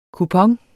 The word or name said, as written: kupon